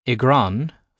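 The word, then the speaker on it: i gcrann: For example, with igran, for in a tree, the C is silent but essential for recognising the original word, cron, in case you want to look it up.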